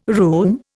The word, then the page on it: room: Indian